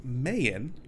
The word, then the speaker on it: Mayan: It is pronounced Mayan. It's pronounced Mayan. They pronounce it Mayan throughout the entire serial, and it's just like, what? – Maybe somebody has trademarks on Mayan. No, they're just saying it wrong.